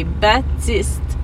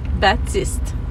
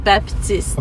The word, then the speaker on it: Baptiste: And by the way, you don't pronounce the P. You say Baptiste. – Yeah. – Baptiste. Not Baptiste.